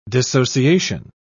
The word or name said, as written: dissociation